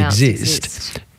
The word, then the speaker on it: IIXIIST: I had no idea how to pronounce it until I saw on your website that it says, I think you even actually say pronounced exist.